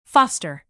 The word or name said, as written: foster